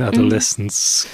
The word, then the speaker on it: Adolescence: Es ist nicht so wie im Deutschen, deswegen Adolescence. Mhm.